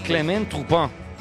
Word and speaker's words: Turpin: Francés Clément Turpin. – Clément Turpin. – Se escribe Turpin, pero se lee en Turpin.